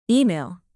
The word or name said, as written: email